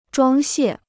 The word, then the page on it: 装卸: zhuāng xiè